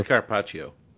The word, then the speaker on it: carpaccio: Carpaccio. I mean, I don't know if I'm saying that correctly, but it's carpaccio.